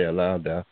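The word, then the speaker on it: Olaudah: You have the slave who was educated that wrote his story, the narrative of the life of Alaudao, depending on how you pronounce it, I say Alaudao, Equiano, Benjamin Banneker, Phyllis Wheatley, Sojourner Truth, Frederick Douglass, Zora Neale Hurston, and Booker T.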